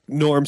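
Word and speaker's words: Gnorm: Norm spelled with a G, silent G, that I watched.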